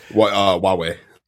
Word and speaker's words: Huawei: Hawaii. Or Huawei or whatever it is, how it's pronounced. – Huawei.